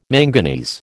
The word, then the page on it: manganese: American English